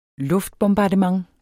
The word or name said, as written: luftbombardement